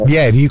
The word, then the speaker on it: Vieri: Un po' come hai detto te, Vieri, sì, così semplice.